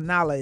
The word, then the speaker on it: Nahla: And then I think it's pronounced Nahla. It's N-A-H-L-A.